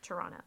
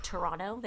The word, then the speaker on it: Toronto: Toronto. I know they pronounce it Toronto. They don't pronounce the T.